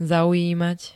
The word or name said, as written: zaujímať